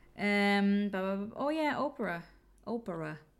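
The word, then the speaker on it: Oparah: How can you not pronounce that right? It's Oprah. – It's spelled Opera.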